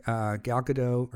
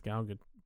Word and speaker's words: Gal Gadot: I think it's Gal Gadot. – I don't think it's Gal. I don't think it's like the feminine version of my name. I believe it is pronounced with a ga.